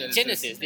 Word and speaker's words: Genisys: How do you say it? Genisys, right? Genisys. They even mentioned it in the movie. It's Genisys. – The spelling is wrong. – Well, the spelling pronounced Genisys.